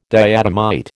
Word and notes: diatomite: American English

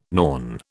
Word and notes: Norn: British English

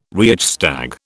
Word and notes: Reichstag: British English

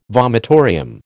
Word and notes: vomitorium: American English

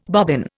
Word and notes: bobbin: Indian

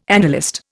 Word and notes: analyst: Indian

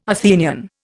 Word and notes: Athenian: Indian

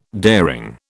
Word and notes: daring: British English

